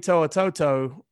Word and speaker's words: To'o To'o: Now, now Henry To'o To'o or Henry Toe, I really don't know how to pronounce his name, and I should have. You did it right, right there. – Okay, I was, I was impressed with that.